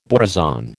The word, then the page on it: borazon: American English